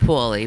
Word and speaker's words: Paulie: I you think it's I mean? pronounced poorly, but okay.